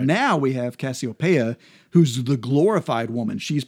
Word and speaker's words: Cassiopeia: Inside the house of Aries, we have Cassiopeia is one of the constellations we're going to talk about. – Okay. – Or Cassiopeia, I guess is some pronounce it.